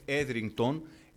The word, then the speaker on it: Etherington: Έτσι θα σας πω, μόνο για να έχετε στο μυαλό σας, υπήρχε μια ερευνήτρια, νομίζω το αγγλιστή το όνομά της λέγεται Etherington, έτσι προφέρεται, η οποία τι έκανε αυτή?